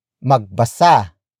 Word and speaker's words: magbasa: In comparison, if basahin becomes an actor focus verb, the sentence becomes magbasa ka ng libro. Magbasa ka ng libro. magbasa ka ng libro. It means read a book. Word for word it's magbasa ka ng libro. Magbasa ka ng libro. Magbasa ka ng libro.